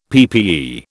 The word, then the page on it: PPE: British English